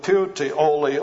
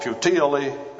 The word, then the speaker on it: Puteoli: Now when Paul travels, they made their way finally to Italy, And he came to Puteoli, or Puteoli, as it has often been pronounced.